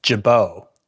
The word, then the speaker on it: Gibaut: It's a hard G. Ian Gibot. – Gibot. It's a pretty cool name. G-I-B-A-U-T.